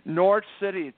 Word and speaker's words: Norwich: Norwich City.